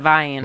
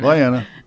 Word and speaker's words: Vienna: All right, not Vienna, but Vienna. – Vienna. Yeah. Like Versailles.